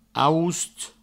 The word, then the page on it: aost: aoust